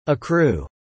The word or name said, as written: accrue